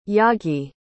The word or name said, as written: yagi